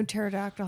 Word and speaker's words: pterodactyl: You know why you can never hear a pterodactyl going to the bathroom? It's because the P is silent.